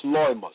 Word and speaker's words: loimos: The Greek word for pestilence is lamas, pronounced loimos, meaning plague or disease.